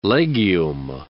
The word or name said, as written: legume